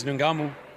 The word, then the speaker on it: Ngannou: Versus Francis Ngannou.